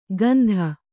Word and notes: ಗಂಧ: gandha

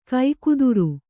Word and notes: ಕೂಗುದಾರಿ: Kannada

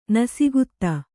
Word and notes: ನಸಿಗುತ್ತ: nasigutta

